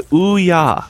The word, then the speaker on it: Ouya: The Ouya, as it's pronounced. – Ouya. – Not Ouya.